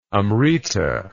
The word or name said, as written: amrita